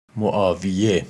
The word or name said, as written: Muawiya